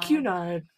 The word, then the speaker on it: Cunard: And I know I pronounced that correctly because I Googled the pronunciation before Cunard. – I did. Cunard.